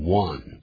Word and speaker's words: One: Lesson 就是课，这个一呢在英文里头念成 One。我们再说一遍好吗？ – Lesson One。 – 当然这个 One 就是 O-N-E，可是我们要念成 One。